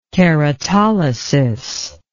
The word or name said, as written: keratolysis